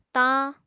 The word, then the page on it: tañ: apatani